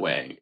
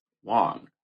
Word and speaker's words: Wang: Oh, sorry, it's not Wang. It's Wong. I feel like I saw this on her Twitter. – It's W-A-N-G. – Is it pronounced Wong? It's W-A-N-G, but it's pronounced Wong.